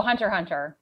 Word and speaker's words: Hunter x Hunter: Oh, Hunter Hunter, because it's got that silent X.